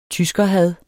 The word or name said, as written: tyskerhad